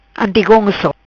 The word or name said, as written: Antigonco